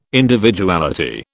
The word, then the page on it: Individuality: British English